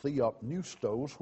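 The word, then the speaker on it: theopneustos (θεόπνευστος): The word inspiration in that verse is a translation of a compound Greek word pronounced theopneustos, which literally means God breathed.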